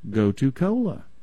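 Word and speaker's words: gotu kola: go-to cola. – Go-to cola, and it's spelled just like you're saying it? – Yeah, G-O-T-U, first word, and then cola with a K.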